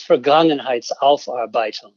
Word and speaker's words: Vergangenheitsaufarbeitung: The word is Vergangenheitsaufarbeitung, which... – I'm sorry. – Believe me, I've had a chance to learn how to pronounce it with a little bit of grace. Vergangenheit is the past. And Aufarbeitung is literally working something off.